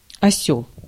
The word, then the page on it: осёл: /ɐˈsʲɵl/; /ɐ.ˈsʲɵɫ/